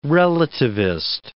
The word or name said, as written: relativist